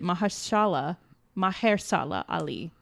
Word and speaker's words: Mahershala Ali: I mean, even according to IndieWire, the Green Book character's family condemns film's hurtful lies based on Mahershala's Ali. Apologies. So I think I butchered it. Mahershala Ali.